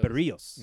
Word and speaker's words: Berríos: Is it Berríos or Berríos? – Berríos. – Yeah, it's like burrito, but with two R's.